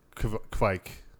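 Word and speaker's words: kveik: I say it, like closer to quack than Kvike, like Kvike.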